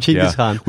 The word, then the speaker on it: Genghis Khan: Well, actually, you kind of pronounce him Genghis Khan. – Genghis Khan. – We actually pronounce him Genghis Khan.